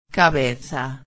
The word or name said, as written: cabeza